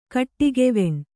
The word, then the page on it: ಕೋಡುಗವಣೆ: Kannada